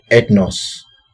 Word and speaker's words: ethnos: You know, the Greek word that is translated nations and Gentiles in most parts of the New Testament is the word ethnos. Ethnos is spelled E-T-H-N-O-S.